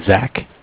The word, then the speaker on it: Zach: My name is Zach, spelled Z-A-C-H. – Okay. – I'm calling from Bank of Cardiff. Cardiff is spelled C-A-R-D-I-F-F.